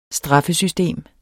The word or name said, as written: straffesystem